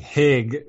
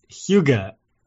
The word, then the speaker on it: Hygge: You probably pronounced it like Hig. – Yeah, wait. How am I supposed to say it? – No, it's Hig Extra. No, it's Hygge.